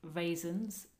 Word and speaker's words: raisin: That's spelt the same as raisins, but pronounced...